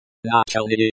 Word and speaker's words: Начальный: Начальный, initial, starting, beginning, adjective, pronunciation. Начальный. Stress is on the second syllable. The L sound is very soft, almost like a W sound.